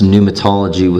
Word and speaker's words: pneumatology: That's pneumatology with a P, kind of like Psalms, the silent P there.